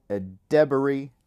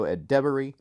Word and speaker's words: Edebiri: It also stars Ruby Cruz, Havana Rose Liu, Kaia Gerber, Io Ed, Ed, I looked this up and I was going to pronounce it correctly and I forgot. Io Edebiri, that's how you pronounce it. Io Edebiri.